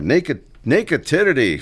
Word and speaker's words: Nakedity: Nakedity from did I say that right? – Danny, yes, you did.